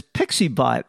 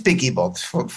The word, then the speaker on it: PiccyBot: The only funny thing about it is, with most people who are blind using screen readers, many screen readers pronounce it as PixieBot. And when I was looking for it in the App Store, I was looking for PixyBot, but I couldn't find it. – And you spell it PICCYBOT, right? – Correct. But it is PickyBot, from picture and then, a picky and then a bot to help you with the pictures.